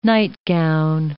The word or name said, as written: nightgown